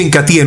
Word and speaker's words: Incatea: Entonces el Incatí, se escribe Incatea, pero se pronuncia Incatí en una forma natural,